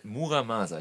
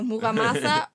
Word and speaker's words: Muramasa: Von, wir reden gerade über äh, Muramasa. Ich habe es gerade falsch ausgesprochen. – Genau, Muramasa.